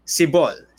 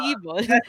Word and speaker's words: Sibol: Okay, I have a hint for you. It's like seagull, but make it ball instead. Sibol.